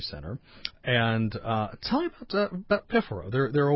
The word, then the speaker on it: Piffaro: Now, the next one coming up, I'm going to ask you to pronounce this because I'm having troubles with is it Piffaro? – It's Piffaro. – Piffaro. Okay.